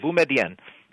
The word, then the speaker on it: Boumediene: Boumediene.